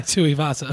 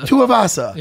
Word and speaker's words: Tuivasa: How do you pronounce his last name? – Tuivasa. – Tuivasa. – Yeah.